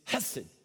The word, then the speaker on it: hesed: Say it with me, hesed. Now, if you didn't spit on somebody near you, you didn't say it right, all right? It's got to come from like your throat, like hesed, all right?